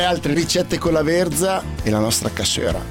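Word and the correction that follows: cassoeula: No, non si dice cassuera, lo dici solo tu, si dice cassuela, sei l'unico che lo dice con la R.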